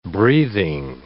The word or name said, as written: breathing